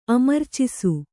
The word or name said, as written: ಅಮರ್ಚಿಸು